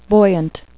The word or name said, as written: buoyant